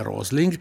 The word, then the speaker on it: Rosling: C'è un bellissimo suo video, lui si chiama Hans Rosling, è facile, si scrive come si dice, Rosling, e dovete cercare The Magic Washing Machine, cioè la magia della lavatrice.